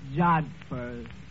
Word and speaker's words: jodhpurs: Absolutely beautiful, Eddie, in her riding pants and her jod hoppers. Yeah, it was love at first sight. – That word is pronounced jodhpurs.